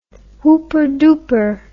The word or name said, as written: whooperdooper